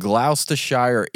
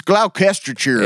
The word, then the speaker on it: Gloucestershire: The cheese rolling event at Copper's Hill in Gloucestershire, England. – Mm-hmm. – I probably said that wrong. – It's Gloucestershire.